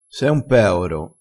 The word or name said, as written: peoro